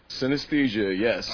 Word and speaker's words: synesthesia: Synesthesia, yes. – Oh, it's like thin and then aesthesia, like... – Synesthesia, yes.